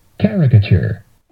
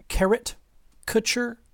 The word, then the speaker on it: caricature: Caricature. – I couldn't possibly count the number of times I've heard this word mispronounced. Carrot, kutcher, anyone? Spelled wrong? – C-A-R-I-T-U-R-E.